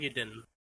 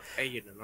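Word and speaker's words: Eiyuden: I do not know how to pronounce this game, so I apologize if I butcher it, but it's called Illudin Chronicle or something? – Ayudin. – I don't know how to say it. – Ayudin Chronicle. – Ayudin, okay.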